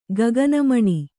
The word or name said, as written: ಗಗನಮಣಿ